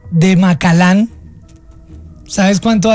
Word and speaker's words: Macallan: Macallan. – Ah, bueno, tú sabes de whisky entonces, porque esa es la pronunciación.